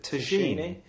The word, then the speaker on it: Tacchini: Is it Tacchini? – Yeah.